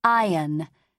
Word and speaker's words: iron: In architect, C-H-I is K. In arch, it is the other way. Please remember to say iron, so that it'll rhyme with lion.